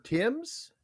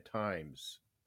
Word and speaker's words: Thames: So it's pronounced Thames. The River Thames? – Thames. – Thames. – Kind of like a short E. – Okay. I was saying times.